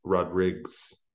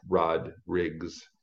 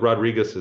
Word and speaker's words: Rodrigues: The S on the end is Rodriguez, is the actual, so it rhymes with King Roes. Like, you know, who goes back in their family tree that far? But basically it's pronounced Rodriguez, but Rodriguez is just as good, and it's you know, natural pronunciation for most of the world.